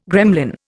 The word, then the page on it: gremlin: Indian